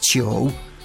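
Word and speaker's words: chou (九): Y en cuanto al 9, su pronunciación en chino es chou, similar a la palabra chou, que tiene el significado de longevidad o larga vida.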